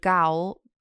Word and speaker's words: Gaol: So 20th of December 1961, Robert McGladdery, 25, was hanged in Crumlin Road, Gaol. How do you say it? The Irish place. Gaol. G-A-O-L in Belfast. – Yeah, that's it. You said it right.